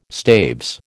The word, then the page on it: staves: American English